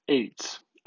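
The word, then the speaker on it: eight (עץ): In Hebrew, the word for tree is eight. You would spell it in English, E-T-S. It sounds like a plural of mount of the number eight, eight.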